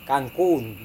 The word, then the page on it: kangkung: Serewen